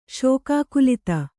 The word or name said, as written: ಶೋಕಾಕುಲಿತ